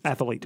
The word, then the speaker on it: athlete: Athlete. – Mm-hmm. Mm-hmm. It's athlete. – Yeah. – No extra A.